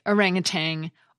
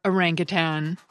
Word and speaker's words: orangutan: It can be pronounced either the way I say it, orangutan or orangutan.